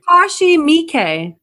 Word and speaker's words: Takashi Miike: Yeah, it's like, I mean, it's like Takashi Miike. – There you go. – Oh, Takashi Miike.